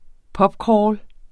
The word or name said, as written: pubcrawl